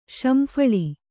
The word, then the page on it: ಸಂಫಲ: samphala